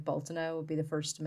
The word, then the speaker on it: Beltane: it but like we would pronounce it Baltana. – Okay. – It's Baltaine. – Okay. – So it looks like Beltane if you were to read it.